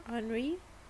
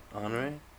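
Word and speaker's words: Honoré: It's honor with an e with an accent at the end. How would you pronounce that? – Henri. – Honor no, e with the accent is a. – Oh. Right? Honore. All right.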